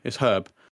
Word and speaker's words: Herb: Anakin JMT says Larry Herb's name, according to him, is pronounced like Herb with the H sound, not with a silent H like the Resident Evil item. Herb. That's an American thing. It's Herb.